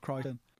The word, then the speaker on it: Crichton: Is that how you pronounce his name? – Yeah. – I'm calling him Crichton. Every episode, you drop something on me that I'm stupid for. I always thought it was Crichton.